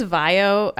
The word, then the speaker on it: VAIO: V-A-I-O. They make computers. – Yeah, I think it's VAIO. I mean, that they've been around for a while, but I think it's VAIO. – That makes sense. And this is for the VAIO Slim Top tablet.